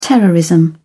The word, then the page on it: terrorism: British English